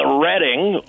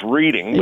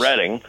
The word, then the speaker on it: Reading: Oh, well, that would be take a ride on the Reading, often mispronounced Reading, but yes. – Reading.